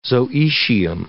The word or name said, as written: zoecium